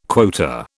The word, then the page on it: quota: British English